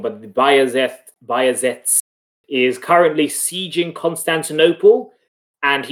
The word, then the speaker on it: Bajazeth: In the Oxford reference of Shakespearean pronunciation, Bajazeth, I think that's it. So it's a Y sound.